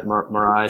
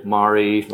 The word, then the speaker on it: Marai: Yeah, so I would say, yeah, Marai. Some people say Mari, so it depends maybe where you're regionally from, but yeah. So, right. So I'm going to call it the Marai because I like that name.